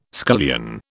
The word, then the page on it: scullion: British English